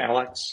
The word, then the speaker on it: Analects: Analects? Is that how you say it? – Analects, yeah. Analects of Confucius.